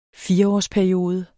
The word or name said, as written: fireårsperiode